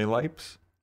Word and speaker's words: Lipes: L-I-P-E-S. Did you say Lipes? – Lipes.